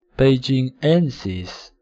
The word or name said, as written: beijingensis